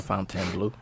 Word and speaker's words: Fontainebleau: La Fontainebleau.